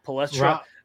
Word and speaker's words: Palestra: The Palestra? I always butcher that name. Palestra.